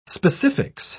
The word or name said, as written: specifics